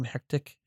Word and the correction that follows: Hecate: Hecate. – Why can't I say words? – I don't know. That one. – Hecate, her disintegration and then into the squid snakes is just beautiful.